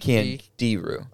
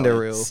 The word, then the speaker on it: Candiru: C A N D I R U. – So what is it called? – Candiru. – Oh, it's definitely Australian. – Candiru. – Definitely Australian. – D I R. – Rhymes with kangaroo.